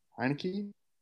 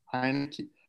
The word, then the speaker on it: Heinicke: Yeah, and you know, Taylor Heinicke played pretty well on Sunday, but wait, what's the quarterback's last name? – Heinicke. – How do you say it? – Heinicke. – There you go.